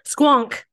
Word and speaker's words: Squonk: The squanch. Is that how you say it? Now I gotta look that up because I can't remember for sure. Squonk. Squonk. That's it.